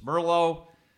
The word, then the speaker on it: Merlo: A lot of people, when you say your name is Merlo, they immediately think it's Merlot, like the wine.